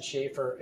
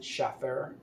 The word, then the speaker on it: Shaffer: And the, I guess that's how you pronounce it, Schaefer, it's spelled S-H-A-F-F-E-R, so you could maybe theoretically pronounce it Schaffer, but I don't think he pronounces it like that.